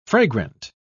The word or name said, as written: fragrant